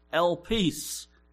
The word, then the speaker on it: elpis: The New Testament Greek word for hope is pronounced elpis. Elpis, spelled E-L-P-I-S.